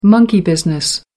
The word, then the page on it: monkey business: American English